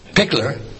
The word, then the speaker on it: Pickler: I had to turn off, like Rod Pickering said, did I say that right? – Pickler. – I'm sorry, Rod.